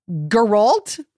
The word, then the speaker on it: Geralt: The hard G. – Geralt? – Yeah. Geralt.